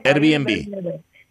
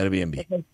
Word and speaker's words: Airbnb: Airbnb. – Eso es. – Airbnb.